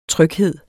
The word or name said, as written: tryghed